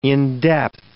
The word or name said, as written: in-depth